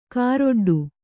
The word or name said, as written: ಕಾರೊಡ್ಡು